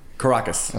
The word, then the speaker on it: Caracas: I just didn't know if it was Caracas or Caracas. – Caracas. – Okay, Caracas sounds... – And they'd be able to tell you exactly how to say it in Doral. – Caracas sounds cleaner than Caracas. – Yes, that sounds like you've got something in your Caracas.